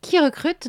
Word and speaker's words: KeyRecrut: Alors, Qui Recrute, donc ça s'écrit comme une clé en anglais, K-E-Y-R-E-C-R-U-T, est un cabinet de recrutement à vrai qui a été créé en 2016.